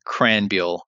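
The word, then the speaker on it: Krahenbuhl: It's a Christian Cranbuehl.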